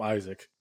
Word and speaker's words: Isak: Alexander Isak, I believe it's pronounced. – Yeah. – I think everyone's just going to call him Isaac.